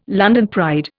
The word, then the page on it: London pride: Indian